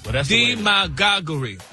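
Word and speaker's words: demagoguery: You got D-E, M-A-G-A-H-G-U-H, re. Demagoguri. – That's the way the dictionary broke it down. – The pronunciation, like the emphasis is on the D.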